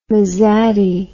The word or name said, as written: mesati